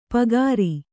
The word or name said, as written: ಪೂಗಾರ